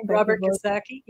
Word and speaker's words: Kiyosaki: I think I'm mispronouncing his name. – Robert Kiyosaki, yeah.